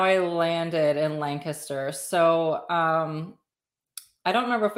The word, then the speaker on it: Lancaster: Yep, Lancaster.